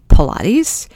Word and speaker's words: Pilates: It is spelled similar to Pontius Pilate, but it's not. It's pronounced Pilates.